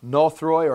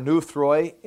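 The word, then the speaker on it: nothroi (νωθροί): And the word dull, you can look on there, further down there on the page, it's the word nothroi or nuthroi, N-O-T-H-R-O-I is the way you'd spell it in the English.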